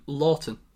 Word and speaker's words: Loughton: Loughton.